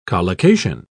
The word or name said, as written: collocation